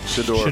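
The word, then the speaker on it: Shedeur: His quarterback, Shooter Sanders, did I say that right? – Shador.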